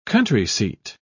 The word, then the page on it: country seat: British English